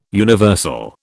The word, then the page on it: universal: British English